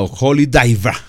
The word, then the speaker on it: Holy Diver: Mejor pronunciado, Holy Diver, así como lo dice en su acento inglés. Holy Drive, Diver, Diver. Así lo pronuncia el gran Ronnie James Dio.